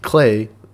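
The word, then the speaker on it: Klei: Speaking of free updates to games, Steve, Clay, it's pronounced like play,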